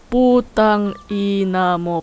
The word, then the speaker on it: putang ina mo: Putang ina mo, putang ina mo, putang ina, ina mo.